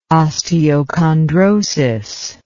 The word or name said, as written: osteochondrosis